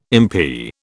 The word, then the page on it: MP: British English